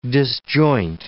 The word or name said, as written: disjoint